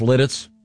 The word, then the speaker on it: Lititz: Are you sure it's pronounced Lititz, Pennsylvania? – Yeah, it's Lititz, pretty yeah.